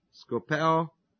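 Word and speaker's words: skopeo (σκοπέω): And the word look here is the word skopeo that you may have heard before in Greek, S-K-O-P-E, long O, skopeo, which means to scope in on.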